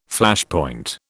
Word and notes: Flashpoint: British English

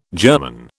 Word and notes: germon: British English

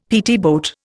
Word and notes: PT boat: Indian

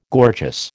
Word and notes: gorgeous: American English

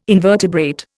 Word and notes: invertebrate: Indian

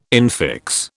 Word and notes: infix: British English

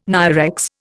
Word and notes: NIREX: Indian

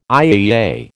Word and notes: IAEA: American English

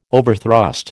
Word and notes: overthrust: American English

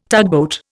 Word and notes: tugboat: Indian

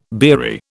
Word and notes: beery: British English

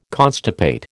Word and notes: constipate: American English

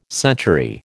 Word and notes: century: American English

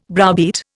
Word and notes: browbeat: Indian

